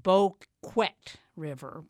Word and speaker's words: Boquet: They're Vermonters, and they had just come from swimming where it's pronounced Boquet River, but it's spelled bouquet, where the river meets the lake.